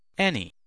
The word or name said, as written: any